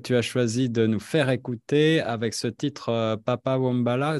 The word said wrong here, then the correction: Papa Dambala: si je le prononce bien. Mhm. c'est Toto Bicente et Papa Dambala. – Papa Dambala.